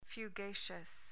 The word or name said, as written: fugacious